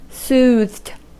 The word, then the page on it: soothed: American English